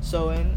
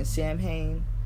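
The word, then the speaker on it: Samhain: But when I first read it, I did keep saying Samhein. I kept saying Samhein because that's literally what it says, but it's not pronounced that way. Like, it's Samhein, but it's pronounced Sowin. So I was thrown off, especially because I smoke.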